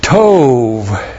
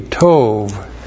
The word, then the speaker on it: tov: The preposition bath means in, and then we have tov. And you've seen this many times. T, long O, B, tov.